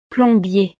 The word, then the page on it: plombier: frances